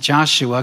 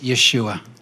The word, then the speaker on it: Yeshua: And it wasn't pronounced Joshua because that's the way we pronounce it. – Also wurde nicht Joshua ausgesprochen, weil so sprechen nur wir ihn aus. – It was pronounced Yeshua.